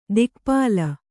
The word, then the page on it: ದಿಕ್ಪಾಲ: kannada; dikpāla